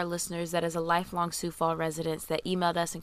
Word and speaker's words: Sioux Falls: We actually mispronounced the city that Daphne was, well, the city that Daphne committed her murder in. The name of the city is actually called Sioux Falls, named after the Native American Sioux tribe.